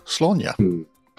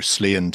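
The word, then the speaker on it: Sláine: It's not pronounced Slain, because I see it all the time. – Slaanya. – Slaanya like Slaanya. – Exactly. – It's kind of like my Aunt Grania.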